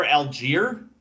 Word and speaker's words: Allgeier: Tyler Allgeier.